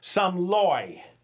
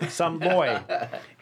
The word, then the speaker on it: Somloi: Reid Somloi. Did I get that right? – Yep. – Somloi.